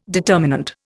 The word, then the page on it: determinant: Indian